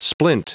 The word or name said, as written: splint